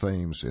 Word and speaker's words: Thames: And before we say anything, it is pronounced Thames in this case and not the British Thames, as you might think.